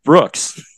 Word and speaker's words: BRX: I'm recording six more songs for an EP that I'm going to release, and it's going to be called, it's pronounced Brooks.